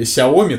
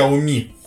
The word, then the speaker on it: Xiaomi: Самое главное, что он, короче, говорит, а, почему вы пришли со своим, значит, говном а, в рай Xiaomi, только бренд-то произносится не так, он произносится Xiaomi.